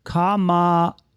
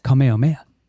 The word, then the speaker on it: Kamehameha: I mean, let me try it again. It's Kamehameha. Oh, Kamehameha. Kamehameha. Kamehameha. – You know I'm going to make it short about this. – Kamehameha.